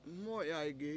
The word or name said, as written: MO7yaygi